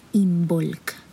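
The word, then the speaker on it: Imbolc: Entonces, si esto te movió el corazón, pues por ahí Puedes buscar información de Involk, se escribe I latina, M, B de bueno, O, L, C, Involk. Así se dice.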